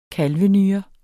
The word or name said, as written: kalvenyre